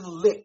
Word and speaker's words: Licht: General manager Jason Licht. Is that how you say it? – Yep.